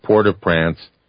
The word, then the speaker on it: Port-au-Prince: Eastern Standard Time, the NBC Brian Williams show had reported an earthquake measuring 7.0 on the Richter scale at Port-au-Prince, Haiti. By the way, that's the French pronunciation for those that want me to say Prince.